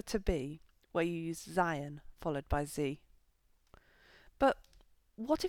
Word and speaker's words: seien: And the command form is neither of these, it's seien. For instance, seien Sie vorsichtig, be careful. Although this often does get pronounced as sein.